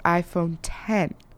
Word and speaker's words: iPhone X: Now, if you read it on the computer, it's typed iPhone X, but it's actually pronounced iPhone 10.